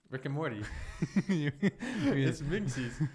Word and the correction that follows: Meeseeks: It's Meeseeks. – Meeseeks.